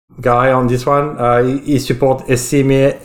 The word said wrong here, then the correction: ECMAScript: Uh, ECMAScript, I think it's usually pronounced.